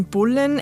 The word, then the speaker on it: Boleyn: Die Aussprache des Nachnamens ist ein bisschen strittig. Manche sagen Boleyn. – Wir sagen Anne Boleyn. – Boleyn dürfte die wahrscheinlichste Variante gewesen sein, wie sie sich selber auch genannt hat.